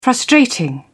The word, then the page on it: frustrating: British English